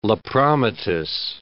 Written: lepromatous

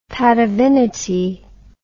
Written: patavinity

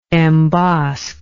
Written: embosk

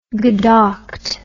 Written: gedackt

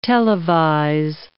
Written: televise

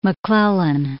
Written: McClellan

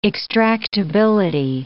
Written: extractability

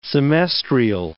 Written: semestrial

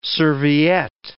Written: serviette

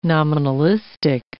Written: nominalistic

